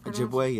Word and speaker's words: Ojibwe: Ojibwe, I would believe it's pronounced. – Ojibwe, yeah.